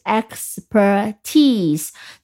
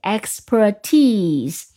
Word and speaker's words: expertise: 那么 expert 它有一个名词，呃的一个变形的一个形式，也是个名词，是专长的意思，就是 expert 后面加上 i s e，但是注意读音，它的读音是 expertise，就把读音放在最后一个音节 expertise。